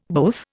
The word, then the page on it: both: Indian